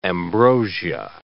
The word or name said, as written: ambrosia